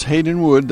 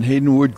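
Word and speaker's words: Haydn Wood: Right, yeah, it's Haydenwood. That's the English pronunciation for what the Germans call Haydn, spelled the same, H-A-Y-D-N. And Haydenwood grew up on the Isle of Man, which is interestingly situated.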